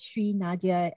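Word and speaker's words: Shrinadja: You can find me on Instagram at Shri Nadia.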